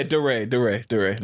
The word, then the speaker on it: DeRay: Yeah, DeRay. No, I forgot that. That's how that nigga say his name.